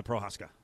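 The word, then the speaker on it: Procházka: Is it Prochazka? – Yeah, that was the guy, the Prochazka.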